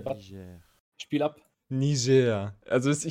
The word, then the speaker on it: Niger: Es wird Niger ausgesprochen. Es ist französisch.